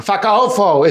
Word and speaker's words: Fakaofo: Uh-huh, and then how do you pronounce that one? – I feel like, can you call some? – Fakafo. – Fakaofo.